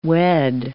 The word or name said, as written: wed